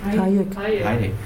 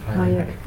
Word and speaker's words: Hayek: Hatsche-Gesellschaft. – Hayek. – Hayek. Entschuldigung. Falsch ausgesprochen.